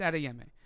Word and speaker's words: Adeyemi: Adeyemi, I think you're right. It's Adeyemi.